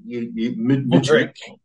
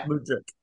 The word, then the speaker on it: Mudryk: Mudryk. Yeah. – Mudryk.